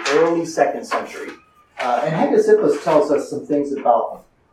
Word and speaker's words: Hegesippus: There's a very early Christian named Hegesippus. gets quoted by some of the early guys. That's if you're taking notes, his name is spelled H-E-G-E-S-I-P-P-U-S, Hegesippus.